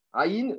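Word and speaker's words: Aïn (עין): L'œil, c'est quel Comment on dit l'œil en hébreu On dit Aïn. Aïn, c'est l'œil. Aïn, l'œil, ça s'écrit Aïn, Yud, Nun, mais on prononce de la même manière que la lettre.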